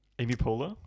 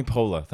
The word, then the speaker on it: Poehler: Is it Pila? This is Amy Poehler. – Oh, Amy Poehler. Thank you. Okay. All right. But I was like P O E H L E R.